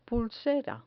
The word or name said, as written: pulsera